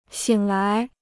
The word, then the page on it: 醒来: xǐng lái